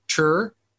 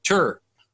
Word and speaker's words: tour: Man, I'm just thinking about, I was talking about this the other day, on how I pronounce the word tour or tur, because I'm from Arkansas. I was writing with Zane and Donna King, and they said something about the word tur, and they're like, wait, you're from Arkansas. How do you say tour? I say tur. Like, who is he? He's from Bald Knob. I'm like, I know. I'm from Baseball, like 30 minutes away. We both say tur.